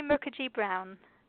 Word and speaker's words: Mukerjee-Brown: It's Lucy Mukherjee Brown.